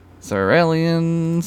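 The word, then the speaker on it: Xyrillians: Is that how that's pronounced? – Is that the weirdos who float inside of people and stuff? – Maybe. It's X-Y-R-I-L-L-I-A-N. – X-Y-R-I-L-L-I-A-N. Zarellians. Zarellians, Zarellians.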